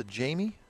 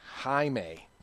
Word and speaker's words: Jaime: Are you sure this is not pronounced the Jamie Garcia? – Jaime. Even though it's spelled J-A-I-M-E, it's Jaime. Okay? – Okay, I got it.